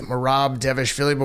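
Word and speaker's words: Merab Dvalishvili: So yeah, I think we're gonna do like four of them, but yeah, Devishev. How you pronounce that man's name? – I'm gonna say Devishvili, Marab Devishvili, but we can also call him Marab for short, since that's his first name.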